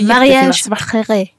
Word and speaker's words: Mariage Frères: Men sen så har Atelieriet jättegott te som heter Mariage Frères. Ja, något sånt. Frères, ja. Det är franskt så det är ju säkert något helt annat uttal. Men det är Mariage Frères. – Ja, tack.